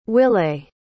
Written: willae

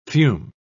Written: fume

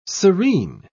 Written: serene

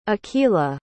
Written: akela

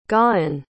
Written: gawyn

